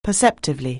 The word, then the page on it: perceptively: anglais